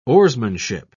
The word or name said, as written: oarsmanship